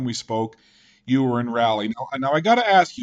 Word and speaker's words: Raleigh: Oh, it's Raleigh. Yeah, definitely Raleigh. I haven't heard anybody pronounce it Raleigh before, so I go with Raleigh, North Carolina.